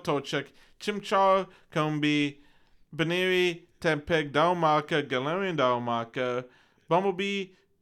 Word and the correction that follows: Bunnelby: I'm saying it right? – Bunnelby. – I'm saying Bumble. – Yeah. – Bunnelby.